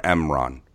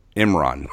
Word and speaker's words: Imran: I clearly lay out how I feel for Emron. Imran.